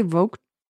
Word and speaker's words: Vogt: Okay, how do you pronounce V-O-G-T? Vote? – Voigt? – Voigt? – I'd say Voigt.